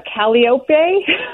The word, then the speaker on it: calliope: But it's interesting, the reason I said that, I don't mean to catch you flat-footed, but he refuses to say calliope, which is how we pronounce it here, because he thinks it's wrong.